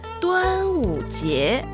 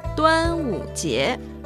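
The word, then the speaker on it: Duan Wu Jie (端午节): Duan Wu Jie, fiesta de bote de dragón. Duan Wu Jie.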